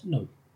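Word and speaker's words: Noh: I think it's pronounced no. – It's no.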